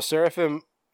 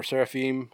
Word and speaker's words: Seraphim: Okay, so Seraphim, or Seraphim, however you want to pronounce it, Inc.